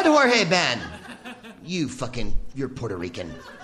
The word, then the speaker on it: Jorge Ben: In Portuguese, the J letter sounds closer to the way you pronounce it in English than to Spanish, by the way. I called Georges Ben... Does anyone speak Portuguese? Thank you. Jorge Ben. I thought it was Georges Ben.